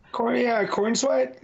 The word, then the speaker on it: Corenswet: David Corenswet. If that's how you say his name. – Cornsweat. That sounds about right.